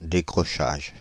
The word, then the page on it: décrochage: Paris; [de.kʁɔ.ʃaʒ]; France (Île-de-France); /de.kʁɔ.ʃaʒ/